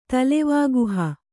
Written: ತಲೆವಾಗುಹ

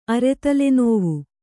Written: ಅರೆತಲೆನೋವು

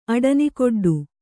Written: ಅಡನಿಕೊಡ್ಡು

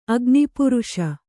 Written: ಅಗ್ನಿಪುರುಷ

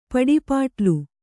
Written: ಪಡಿಪಾಟ್ಲು

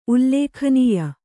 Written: ಉಲ್ಲೇಖನೀಯ

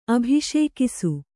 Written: ಅಭಿಷೇಕಿಸು

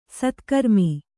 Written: ಸತ್ಕರ್ಮಿ

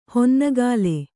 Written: ಹೊನ್ನಗಾಲೆ

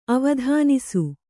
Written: ಅವಧಾನಿಸು

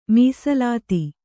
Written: ಮೀಸಲಾತಿ

